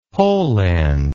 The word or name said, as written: pollend